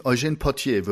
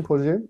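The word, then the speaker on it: Eugène Pottier: Die äh, wurde geschrieben von äh, Eugen äh, helf mir mal mit der Aussprache. Portier? – Eugène, Eugène Portier würde ich das aussprechen. Eugène Portier, ja.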